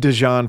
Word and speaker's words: Dijon: And Dijon, for the listener, it's spelled like the mustard, correct? – It is.